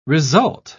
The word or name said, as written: result